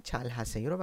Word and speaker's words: 잘 하세요: دقت کنید که این 잘 하세요 رو وقتی میخوای سریع تلفظ کنی، این ه صداش از بین میره و ل صدای ر میگیره.